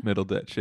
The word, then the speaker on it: Middleditch: No Thomas Middleditch, but I'd be is that how you pronounce his last name? – Middleditch, yeah.